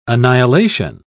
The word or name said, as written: annihilation